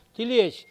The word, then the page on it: kiliej: Mokilese